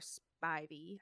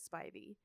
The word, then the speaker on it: Spivey: I always don't know how to say it, but they even said it on there, and it is Spivey. – It's Spivey, and I just think of it as Ivy with a sp. – I think of Spider. Spivey.